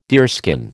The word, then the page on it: deerskin: American English